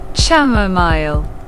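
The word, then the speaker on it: chamomile: Как оно произносится? Чамомайл. – Чамомайл. – Так произносится.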